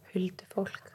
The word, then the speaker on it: Huldufólk: So today I thought it would be fun to give our listeners a little Icelandic history lesson into the origins of the Huddlefolk, aka the hidden people. Did I say that okay? – Yeah. – How would you say it? – Huldufolk. – Huldufolk. – Yeah. – That's better.